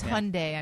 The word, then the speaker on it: Hyundai: It's Hyundai, I'm just saying. – Hyundai? – I did a commercial, and they told me it's Hyundai. It rhymes with Sunday.